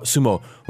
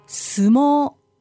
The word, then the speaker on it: sumo: I think it's interesting.、はい、so we're going to be talking about sumo. What is the correct pronunciation of sumo? – Sumo. Sumo. – So it's a long O on the second. – Yes. – It's not sumo.